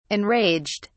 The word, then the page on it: enraged: British English